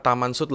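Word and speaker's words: Tamám Shud: Dan yang kertas itu tuh bertuliskan dua kata yaitu Tamam Sud atau biasa dibaca Taman Sud lah kalau orang sana tuh bacanya Taman Sud walaupun tulisannya Tamam ya.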